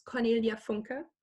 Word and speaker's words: Cornelia Funke: It's a fourth book to a series that's called Inkheart by the German way pronounced, it's Cornelia Funke.